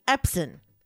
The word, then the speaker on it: Epson: E-P-S-O-N. – E-P-Epson? – Samson. – All right, whatever. – Samson? – Samson. Epson, in. It's an I-N, even though it's O-N.